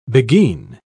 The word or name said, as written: beguine